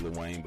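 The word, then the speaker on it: Luane: It's pronounced Lil Wayne, but it's not like the rapper. So it's Rodrick, R-O-D-R-I-C-K, no E, L-U-A-N-E, Butler, B-U-T-L-E-R.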